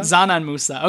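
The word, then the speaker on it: Džanan Musa: It's a silent D, right? – I always pronounce, I hear it Zanan Musa. – Zanan Musa. Okay, that makes more sense.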